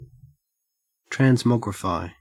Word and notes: transmogrify: Australian English

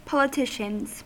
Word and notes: politicians: American English

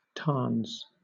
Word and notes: tarns: British English

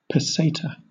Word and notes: peseta: British English

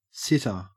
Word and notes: sitter: Australian English

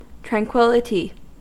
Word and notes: tranquillity: American English

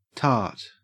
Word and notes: tart: Australian English

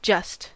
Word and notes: just: American English

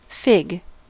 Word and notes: fig: American English

